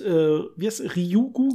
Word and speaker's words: Ryugu: Der Asteroid, Ryugu, glaube ich, wird er ausgesprochen, Janis? – Genau.